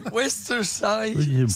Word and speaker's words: Worcestershire: La sauce anglaise, c'est bien la Worcestershire sauce, c'est pas voilà. J'ai pas osé le dire. – Vous qui revenez d'Angleterre, vas-y, prononcez-la pour nous faire rire. Voilà. Comment on l'appelle la sauce? – Worcestershire sauce.